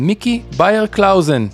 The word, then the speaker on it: Beyer-Clausen: My guest in this episode is Mickey Beyer-Clausen, co-founder and CEO of Timeshifter.